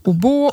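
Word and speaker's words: oboe: Det handlar framförallt om svårigheter att rekrytera personer som kan spela obo och fagott. – Oh ja, det uttalas så.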